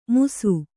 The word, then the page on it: ಮುಸು: musu